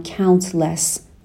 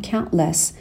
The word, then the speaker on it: countless: دقت بکنین که نمیگم countless میگم countless دیگه اون تی رو خیلی پررنگ تلفظ نمی کنم